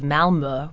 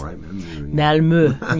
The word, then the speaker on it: Malmö: Malmö. Yes, they would probably tell me I'm still not pronouncing it perfectly. Swedish vowels are notoriously difficult to capture for non-Swedish speakers.